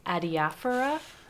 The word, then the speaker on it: adiaphora: Adiaphora? – Yes.